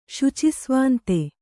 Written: ಶುಚಿಸ್ವಾಂತೆ

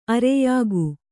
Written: ಆರ್ಯಕೆ

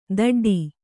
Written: ದಡ್ಡಿ

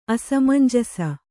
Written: ಅಸಮಂಜಸ

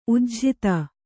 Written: ಉಜ್ಝಿತ

